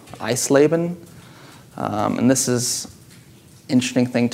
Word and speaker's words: Eisleben: Eisleben.